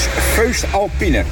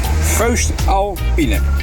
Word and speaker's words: Voest Alpine: Ik zei, hoe spreek je dat uit? Dat Voest Alpine. Nou, let op, daar komt het. – Dat is Voest Alpine. Voest Alpine.